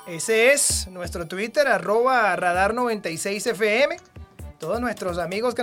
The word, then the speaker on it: Twitter: No se dice Twitter, se dice Twitter.